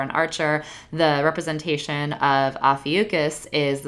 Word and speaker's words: Ophiuchus: And in the spirit of Scorpio season, since it follows Scorpio, it's actually called Ophiuchus.